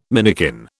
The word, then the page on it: minikin: British English